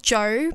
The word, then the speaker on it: Djo: And the band is called Joe, but it's spelled D-J-O.